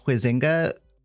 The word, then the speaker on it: Huizinga: One of the first uses of the term comes from Johann Huizinga, which I may be saying wrong because I've only ever seen it read. So sorry, Johann, if I'm mispronouncing it. – Rhymes with Bazinga.